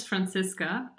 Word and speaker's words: Franziska: My name is Franziska. Uh, it's German, so it's very hard to pronounce it, I think, in, in general, but you can call me Fran for this conversation if you want to.